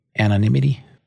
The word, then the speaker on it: anonymity: First one is anonymity. Anonymity? Did I say that right? – Anonymity? Sure.